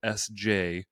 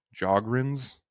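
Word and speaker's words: Sjögren: I think it was a silent S at the beginning. So SJOGREN, like Sjogren's syndrome or something.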